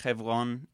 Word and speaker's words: Hevron: Hebron, pronounced Hevron in Hebrew, actually means friend or comrade.